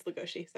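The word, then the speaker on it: Legoshi: There was a really funny bit, though, where Dad walked in on me watching the scene in Beastars where Lugosi, or is it Lugosi? It's Lugosi, sorry. Yeah. They spell it like Bela Lugosi. – Yeah. – In the manga.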